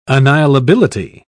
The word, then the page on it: annihilability: British English